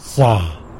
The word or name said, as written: za